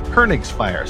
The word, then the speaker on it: Koenig's Fire: You'll notice that I have now fixed the pronunciation of the novel title. I think all along I was doing the homeschool pronunciation of the title, but it turns out friend at church knew a little German and got to let me know that it is pronounced Koenig's Fire.